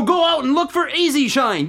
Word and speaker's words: E-Z Shine: It's not the word easy written out. It's the letters. It sort of sounds like it when you pronounce the Z. It sounds like Easy Shine.